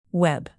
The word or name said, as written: web